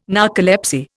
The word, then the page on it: narcolepsy: Indian